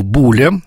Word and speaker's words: Буле: И вот его любимым профессором был как раз геттингенец Иоганн Теофил Буле. Ударение на первый слог, часто неправильно говорят.